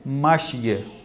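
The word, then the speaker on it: ماشية: تو اگر اس کو روک کے پڑھیں تو کیا پڑھتے ہیں؟ ماشیہ. درست ہے، تلفظ جو ہے اس کا عربی یوں ہے.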